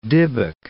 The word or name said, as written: dybbuk